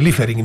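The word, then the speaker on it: Liefering: Λίφερινγκ. – Ναι. – Είσαι σωστός. Αυτή ναι. Είναι, έτσι προφέρεται, Λίφερινγκ, ναι.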